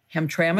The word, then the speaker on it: Hamtramck: Hamtramck.